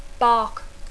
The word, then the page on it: bark: English